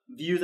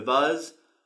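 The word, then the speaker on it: Vuze: And I forgot to mention before, we have the Views. I always pronounce it wrong, but I think it's the Views or the Vuzz, which comes out soon. It's V-U-Z-E.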